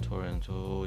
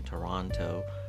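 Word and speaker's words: Toronto: اما این فقط در حد تلاش‌های این فرد محدود شد و الان تورنتو یا همون تورانتو که تلفظ درست این شهر هست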